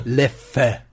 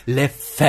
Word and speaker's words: Leffe: Ah, bei Leffe spricht man das letzte eh nicht aus, wurde gerade gesagt. – Leff. Fuck, fuck you, ich kann's. Leffe. – Soll ich nochmal? Leffe.